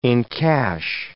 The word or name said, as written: encash